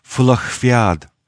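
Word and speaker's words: Fulacht Fiadh: Well, it looks like Fulacht Fiad, but in fact it's pronounced Fulacht Fiad.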